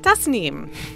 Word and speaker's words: Tasneem: Tasneem rhymes with bus.